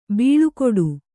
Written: ಬಾಳುಗೇಡಿ

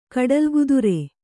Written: ಕಡಲ ಕುದುರೆ

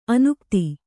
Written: ಅನುಗತಿ

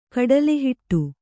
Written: ಕಡಲೆಹಿಟ್ಟು

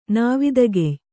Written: ನಾವಿದಗೆಯ್

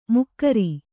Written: ಮುಕ್ಕರಿ

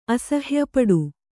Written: ಅಸಹ್ಯಪಡು

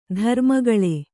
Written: ಧರ್ಮಗಾಳೆ

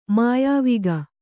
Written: ಮೆಯ್ವೋಗ

